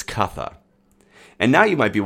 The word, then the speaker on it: Cutha: with C being a CH sound, we also have this Cutha fellow in Wessex, and we have Creota in Mercia. And there the C is pronounced with a hard C rather than a CH.